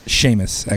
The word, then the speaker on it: Seamus: Jameis. Seamus. Okay. It's Seamus, actually, I guess is pronounced.